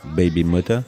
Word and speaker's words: Bbymutha: Qui s'appelle Baby Moda. – Mother. – Ça s'écrit B-B-Y-M-U-T-H-A. Baby Moda.